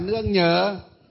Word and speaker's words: nương nhờ: Lương nhờ ạ. Hay không? Thầy đọc đúng mà. Thầy đọc đúng mà. Lương nhờ hay là nương nhờ? Nương nhờ chứ không phải là lương nhờ.